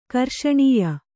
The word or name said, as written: ಕರ್ಷಣೀಯ